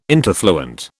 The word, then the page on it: interfluent: British English